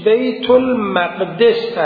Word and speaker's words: بیت المقدس: که گفتن تلفظ صحیحش بیت المقدس است، یعنی از باب اضافه،